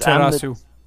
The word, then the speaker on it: Amaterasu: Amaterasu.